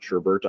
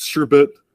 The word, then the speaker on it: sherbet: Spell check is letting me know that it's definitely spelled sherbet, right? Not sherbert. I always say sherbert.